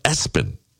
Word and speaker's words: ESPN: But someone named their kid ESPN. It's pronounced Espen.